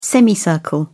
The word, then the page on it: semicircle: British English